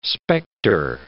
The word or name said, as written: spectre